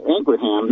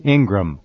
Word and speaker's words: Ingraham: But as reported by Fox News anchor Laura Ingraham, not quoting the alternative. – It's actually, just for fun, it's Ingram. – Yeah. – She doesn't pronounce Ingram. The ham part.